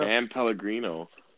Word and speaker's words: San Pellegrino: San Pellegrino, that's how you say it? – Yeah. San Pellegrino.